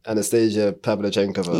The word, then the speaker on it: Anastasia Pavlyuchenkova: Anastasia Pavlyuchenkova.